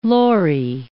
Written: lorry

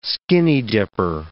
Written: skinny-dipper

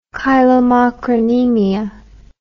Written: chylomicronemia

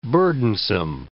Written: burdensome

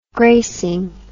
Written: gracing

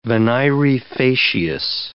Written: venire facias

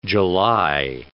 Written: July

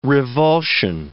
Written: revulsion

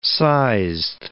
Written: sized

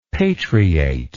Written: patriate

